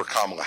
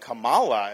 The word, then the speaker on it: Kamala: A vote for Donald Trump is a vote for Kamala Harris. – If we muff this one and, and Biden gets in again, heck, you may end up with Kamala as president. It's actually pronounced Kamala.